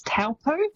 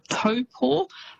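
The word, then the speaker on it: Taupō: but famous spots including Taupo. So quite often you'll hear that said as Taupo. – Yes. – It's almost how it's spelt, but that Taupo is actually pronounced Taupo.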